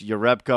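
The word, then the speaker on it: Jerebko: But I think when you see young players or a new player, when you saw Jarepko, by the way, you know, I guess that's the way we should be pronouncing it, is Jonas Jarepko, although we're not always hearing that.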